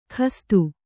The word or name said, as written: ಗಸ್ತು